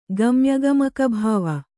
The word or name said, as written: ಗಮ್ಯಗಮಕಭಾವ